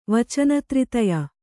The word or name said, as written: ವಚನತ್ರಿತಯ